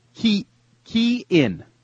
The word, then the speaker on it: Cian: Key in Galway, Ireland. It's spelled C-I-A-N, but apparently it's Key in Galway, Ireland.